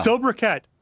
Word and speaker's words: sobriquet: Sobriquet. Last hour, I said sobriquet, and then afterwards was like, "There's no way that Q U is pronounced like a Q U."